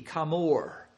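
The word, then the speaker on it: Kamor (חמור): The word for ass is Kamor, K-H-A-M. You'd spell it in English, -O-R-E, Kamor.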